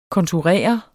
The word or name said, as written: konturer